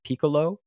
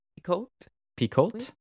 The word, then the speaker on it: Picoult: How do you say her name? Piccolo? I think you don't pronounce the T, right? It's French? – Picoult? – Yeah, butchered it.